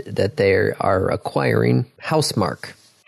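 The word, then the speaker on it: Housemarque: Okay, Housemark, but it's spelled M-A-R-Q-U-E. – Yes, they're Finnish.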